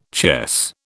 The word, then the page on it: chess: British English